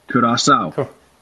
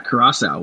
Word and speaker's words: Curaçao: We're gonna hear Wikipedia pronounce the country's name. I don't want to get it wrong. Curaçao. Okay, yeah, Curaçao.